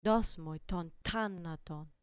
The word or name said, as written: dos moi ton tanaton